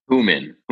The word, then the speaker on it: Houman: Houman. – Houman, like Houman Tashin. – Like Houman, yeah, there you go.